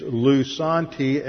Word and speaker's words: lusanti: Some manuscripts have the top word, which is lusanti, L-U-S-A-N-T-I. Other manuscripts have lusanti, pronounced the same way, L-O-U-S-A-N-T-I.